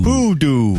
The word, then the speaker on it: Pudu: P-U-D-U. Puda, right? Pude? – Pudi? – Pudi? You're the smart one. – You said P-U-D-A. I would say P-U-D-U. – Oh, Pudu. – Pudu. – Pudu.